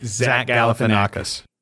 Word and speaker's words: Zach Galifianakis: Zach Galifianakis. – Wow, you even pronounced it pretty well.